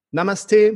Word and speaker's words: Namaste: Namaste, mein lieber Alex.